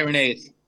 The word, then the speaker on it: Irenaeus: I always pronounce his name wrong. – Irenaeus. – I think it's Irenaeus. – Irenaeus.